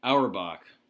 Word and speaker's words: Auerbach: Auerbach.